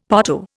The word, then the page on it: potto: Indian